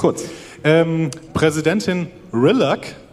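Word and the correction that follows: Rillak: leitet das Treffen. Rillag heißt sie, äh, ist Bajoranerin, Kardassianerin und Mensch.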